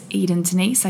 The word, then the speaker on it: Edyn Denise: My Instagram is Edyn Denise. I spell it E-D-Y-N-D-E-N-I-S-E.